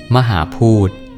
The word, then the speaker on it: มหาภูต: วัสดีครับพบกับรายการคำวัดวันนี้ขอเสนอคำว่ามหาพูดคำว่ามหาพูดสะกดด้วยมอม้าหอหีบสระอาพอสำเพาสระอูตอเต่าอ่านว่ามหาพูด